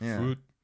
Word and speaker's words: Frute: Of the band Frute. And that's how you say it, right? It's just exactly as it looks, right? – Yes.